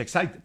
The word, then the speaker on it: Exide: Se escribe Exide, se pronuncia Excite, dentro decía el comercial de las baterías.